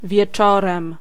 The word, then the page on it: wieczorem: [vʲjɛˈt͡ʂɔrɛ̃m]